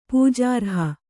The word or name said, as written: ಪೂಜಾರ್ಹೆ